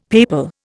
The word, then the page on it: papal: Indian